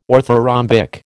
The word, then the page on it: orthorhombic: American English